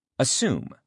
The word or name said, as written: assume